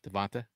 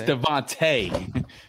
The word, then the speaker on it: DeVonta: Devonta? – It's Devontae.